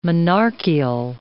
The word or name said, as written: monarchial